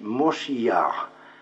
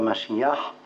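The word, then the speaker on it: Moshiyar (מושיע): En hébreu, cela se dit Moshiyar, non pas Mashiyar, mais Moshiyar.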